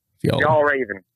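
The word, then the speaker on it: Fjällräven: It's like Fjall with a Y after F. Fjall. – Fjall Raven.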